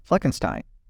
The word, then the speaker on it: Fleckenstein: I am your chair for the MLS 2023 Board of Directors, and I am with my vice chair, John Fleckenstein.